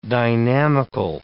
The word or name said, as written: dynamical